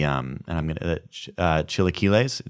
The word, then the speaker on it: chilaquiles: You're gonna if you're gonna get the burrito, you have to get the chilaquiles.